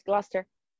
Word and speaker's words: Gloucester: No, it's Gloucester.